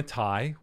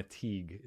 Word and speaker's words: Tighe: Hannah Ty, which I had pronounced her name incorrectly 75% of the show. – Yeah. – And then she went in because I called her Hannah Teague. It's spelled T-I-G-H-E.